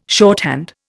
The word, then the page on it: shorthand: Indian